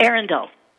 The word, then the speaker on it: Arundel: It's like a castle from, I hope I'm pronouncing this correctly, Arundel, England? – Arundel.